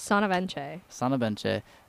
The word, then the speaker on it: Asana Benche: Asana Benche.